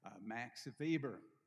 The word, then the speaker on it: Max Weber: The way you pronounce in German, the W is the V, Max Weber.